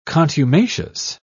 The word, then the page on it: contumacious: British English